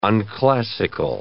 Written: unclassical

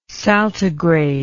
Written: saltigrade

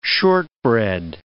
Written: shortbread

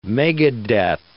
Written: megadeath

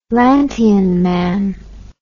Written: lantian man

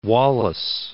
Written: Wallace

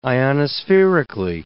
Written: ionospherically

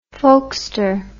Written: folkster

